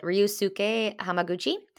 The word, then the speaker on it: Ryusuke Hamaguchi: So it's directed by, I'm going to butcher this, but Ryusuke Hamaguchi. – I think that you nailed it.